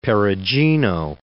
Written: Perugino

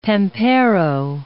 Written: pampero

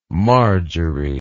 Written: marjory

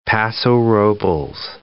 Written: Paso Robles

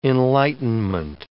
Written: enlightenment

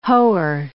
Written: hoer

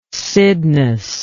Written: cydnus

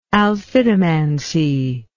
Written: alphitomancy

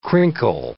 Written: crinkle

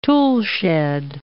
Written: toolshed

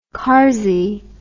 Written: karsey

